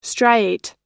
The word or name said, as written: striate